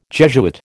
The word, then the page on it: Jesuit: American English